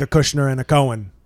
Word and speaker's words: Cohen: But Cohen, that's a little extra. Yeah, the extra Jewish syllable.